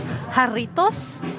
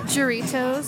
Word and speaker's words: Jarritos: My Mexican heritage, it's Jarritos, not Jarritos.